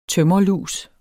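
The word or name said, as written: tømrerlus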